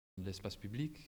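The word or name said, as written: espace public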